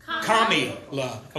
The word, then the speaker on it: Kamala: If Kamala was not for murdering babies, if Kamala didn't want to take our guns, if Kamala, or is it, I'm pronouncing it right? – Kamala.